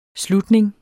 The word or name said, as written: slutning